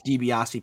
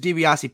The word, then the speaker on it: Dibiasi: Is just Dibiasi, Peter. Just my last name, first name. And that's my main Twitter account. Dibiasi, I said it right.